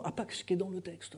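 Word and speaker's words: hapax: Le mot, et c'est intéressant de voir que le mot grec qui veut dire une seule fois, c'est le mot apax, est devenu un mot français que vous connaissez tous, qui est plutôt utilisé en linguistique. Et apax, avec un H, bon, que l'on ne prononce pas en français,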